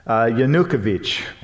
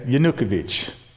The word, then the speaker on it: Yanukovych: I hope he doesn't get mad at me, but it's Yanukovych, okay? Yanukovych. That's how you say the name, Yanukovych. That's my American pronunciation. You know, over there you say it, you emphasize the vich a little more.